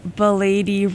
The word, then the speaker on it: Belady: I was just informed that it is a three-syllable Belady rhymes with m'lady.